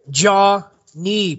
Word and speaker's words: Jonny: When I was out in Thailand, the Thais, you know, they wouldn't say my name like Jonny. They would always say it like they'd really enunciate the Jonny. – Oh, Jonny. Okay. – So I was like, it made me think. I was like, oh, it almost sounds like they're saying like a jaw and a knee.